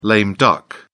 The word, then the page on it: lame duck: British English